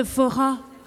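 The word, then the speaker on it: forró: On va faire ce qui s'appelle un foro. Un fora. Je le prononce très très mal. Vas-y, comment... – C'est le fora.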